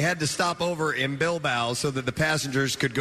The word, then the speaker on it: Bilbao: All right, so a plane from Gran Canaria to the UK had to make an unscheduled stopover in Bilbao. – Bilbao. – I guess, I don't know, Nick, is this how you pronounce that? B-I-L-B-A-O? – Yeah, it's in southern Spain.